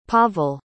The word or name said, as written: Pavel